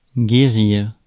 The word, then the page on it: remedy: /ˈɹɛm.ə.di/; American English